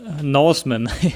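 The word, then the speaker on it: The Northman: Northman, also quasi wie jemand, der kein TH kann, Northman aussprechen würde.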